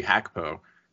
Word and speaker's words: Gakpo: Speaking of, you know, smaller swings, though, I said Gakpo earlier. Cody Hakpo, which is how you pronounce his name, because that's how G's go in Dutch, would have been, what, 20 to 25 million euros?